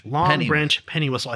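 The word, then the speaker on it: Longbranch Pennywhistle: I cannot pronounce that for life. – Long Branch Penny Whistle. – I think I pronounced it wrong like 6 times in a row right there.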